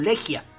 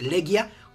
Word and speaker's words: Legia: Muchos lo leen como Legia. La pronunciación en polaco es Legia, como sea.